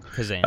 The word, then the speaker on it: Kazan: Kazan. – Kazan, yeah.